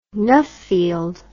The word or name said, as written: Nuffield